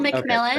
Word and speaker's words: McMillan: McMillan? No. I'm saying that right, right? – Yep, PL McMillan.